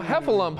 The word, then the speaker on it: Heffalump: Pooh's Heffaloop. Halloween. – Oh, Heffalump. – Heffalump.